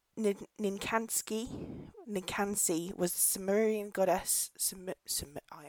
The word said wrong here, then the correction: Ninkasi: Ninkasi was the Sumerian goddess of brewing.